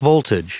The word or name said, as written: voltage